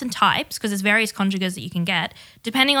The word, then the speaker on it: congeners: It's actually congeners, by the way. That's how it's pronounced.